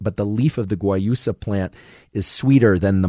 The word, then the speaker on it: guayusa: Some people who don't like mate might prefer something like guayusa, which is spelled G-U-A-Y-U-S-A. G-U-A-Y-U-S-A, guayusa, which is from Ecuador, despite the USA ending to it.